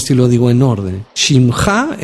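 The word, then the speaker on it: Shimcha (שמך): Entonces Shimcha, nombre tuyo, Shimcha. Son tres letras, Shin, Mem y Haf, Sofit. Shimcha, Shimcha. Así se pronuncia, nombre tuyo, nombre tuyo.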